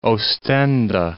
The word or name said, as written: Oostende